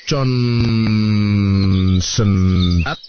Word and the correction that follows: konsentrat: Bacanya konsentrat, Pak.